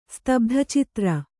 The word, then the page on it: ಸ್ತಬ್ಧಚಿತ್ರ: Kannada